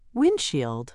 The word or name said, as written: windshield